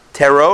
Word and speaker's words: tarot: Palm reading, you know, reading palms, or those, how do you pronounce it? The T-A-R-O-T? Tarot or tarot? Tarot? Yeah. Like the chips? No, it's tarot.